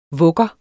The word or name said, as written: vugger